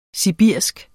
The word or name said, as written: sibirisk